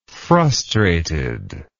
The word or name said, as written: frustratedly